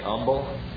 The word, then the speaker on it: humble: Some of you ever hear people, when they pronounce the word humble, some of them just say humble. I've heard many different people that do it that way. But they say humble, they don't even pronounce the H.